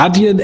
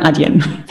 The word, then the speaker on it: Adyen: Wie ist die offizielle Aussprache? Adyen, Adyen oder wie nennt ihr euch genau? Adyen? – Das variiert sogar bei uns in den Büros. Also die Amis sagen immer Adyen und wir sagen Adyen.